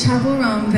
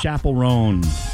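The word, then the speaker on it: Chappell Roan: If you've been saying Chappelle Rowan, this is your final warning. It's Chappelle Rowan, babe. – It's Chappelle Rowan. – Yeah, I don't know why anybody said Chappelle Rowan.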